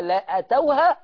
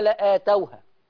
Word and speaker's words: لآتوها: في سورة الأحزاب، الكلمة دي في سورة الأحزاب، ولو دخلت عليهم من أقطارها ثم سئلوا الفتنة لأتوها. غلط. لأتوها خطأ، لآتوها هكذا تنطق، لآتوها. ندي الهمزة حقها والألف المادية حقها، تمام كده؟